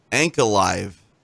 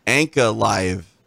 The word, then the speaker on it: Ankalaev: Did you say Ankalaev? – Ankalaev. – Okay. Let's hear. Let's hear how he says it. – Magomed Ankalaev. Magomed Ankalaev. – Nah. – What do you mean, nah? Ankalaev. I said it correctly. – Ankalaev. – That's not what he says. – Ankalaev. – I don't hear him say that. – Yeah. There's a KF. And I hear the four syllables there.